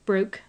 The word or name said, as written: broke